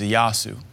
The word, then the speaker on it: Ieyasu: The ironic thing is one of the guys that rose up was a Lord Ieyasu, spelled Ieyasu, which ironically is exactly how they pronounce Jesus in Japanese, is Ieyasu.